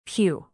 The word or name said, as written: phew